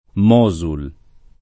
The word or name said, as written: Mosul